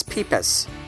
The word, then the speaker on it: Pipas: My name is Peepas, but call me Pipes.